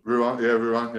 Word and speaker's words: Rouen: Rouen? – Rouen, yeah.